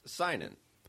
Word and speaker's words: Sinan: Say it right. – Sign in. – Sign in. – Yeah, like sign in your name.